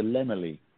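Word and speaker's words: Laemmle: It's the Lemily. – Lemily. – Yeah, the Lemily. It's the Santa Monica branch of the Lemily chain.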